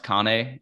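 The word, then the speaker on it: kāne: and it would say I think it's pronounced Kane in uh, in the Hawaiian language.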